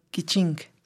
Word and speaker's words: Kichink: Es K-I-C-H-I-N-K, como Kichi, Kiching.